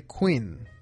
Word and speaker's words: Quinn: El nombre Queen, perdón, ha sido de los nombres más populares, pero igual que puede ser para hombres o para mujeres, el nombre Queen, que se escribe Q U I N N.